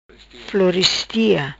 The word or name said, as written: Floristia